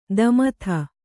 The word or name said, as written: ದಮಥ